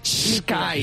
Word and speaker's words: Skype: Esto también Lucía lo sabrá porque en inglés la S, cuando va a S al principio, nosotros tendemos los españoles a pronunciarla con E. – Claro, Skype. – Claro. – Pues no. Es una S fluida. Es Skype.